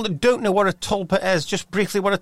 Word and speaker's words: tulpa: How do you pronounce it? – Tulpa. It's I think it's T-U-L-P-A, Tulpa.